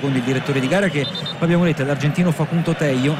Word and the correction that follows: Tello: anzi Tejo, considerato che in Sud America la doppia L non si legge Ye ma si legge Sh,